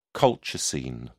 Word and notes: colchicine: British English

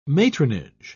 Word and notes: matronage: British English

